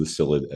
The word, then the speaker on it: psyllid: P-S-Y-L-L-I-D, psyllid, the P is silent,